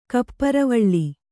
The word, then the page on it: ಕಪ್ಪುರವಳ್ಳಿ: Kannada